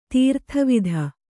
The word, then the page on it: ದುರ್ದೇವತೆ: durdēvate